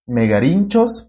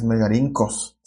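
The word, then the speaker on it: megarhynchos: lleva CHO, que pues, debería ser megarinchos, pero no, es megarincos.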